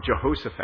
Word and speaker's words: Jehoshaphat: And so he sought the alliance of King I want to get it right, because last week I said it wrong. Jehoshaphat. I said Jeroboam a couple of times, not meaning to, and it's, you know, they're all J's, they're all the same.